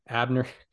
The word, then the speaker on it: Abner: Abner. How about like that? – yeah, Abner. Just just like him. – Just, just like just him. like Abner Doubleday.